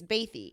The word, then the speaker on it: Bathé: That's actually the pronunciation is Bathé.